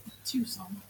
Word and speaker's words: Tucson: Tucson. – Tucson.